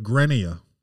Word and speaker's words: Grenier: And if you're not French, I think you just call it Grenier, you know? But, you know, if you want to keep that French pronunciation, you go ahead.